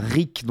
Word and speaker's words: Reak: c et 2 en chiffre romain, donc vous prononcez ça comme vous voulez, par Rick, donc R-E-A-K,